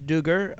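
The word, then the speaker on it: Duggar: Josh Dugar, and apparently that's how you pronounce his name.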